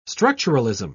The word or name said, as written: structuralism